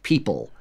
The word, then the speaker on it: 37d03d: which has just come out on the record label that is spelled 37D03D, but which is pronounced people.